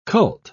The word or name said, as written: cult